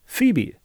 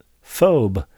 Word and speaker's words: Phoebe: There was a word I'd never encountered, Phoebe. So I spent part of my childhood pronouncing it phobe, like lobe.